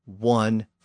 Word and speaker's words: one: By the late 1600s, most of these W pronunciations had disappeared, but for some unknown reason, they survived in the words one and once. And by the 1700s, that initial W sound in those words had become standard throughout English.